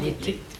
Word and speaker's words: été: L'été. – Oui, prononcez ça. Et analysez ce que vous prononcez. Est-ce que le premier ré, qui est inaccentué, est aussi fermé que le second ?